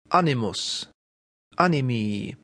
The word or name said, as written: animus